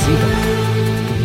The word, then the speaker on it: Zeva: Uh, Ziba is spelled Z-E-V-A, right? But it's Ziba. Neva is spelled N-E-V-A.